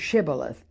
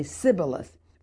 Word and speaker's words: Shibboleth: Then they would say to him, 'Then pronounce the word Shibboleth.' And he would say, 'Sibboleth,' for he could not pronounce it."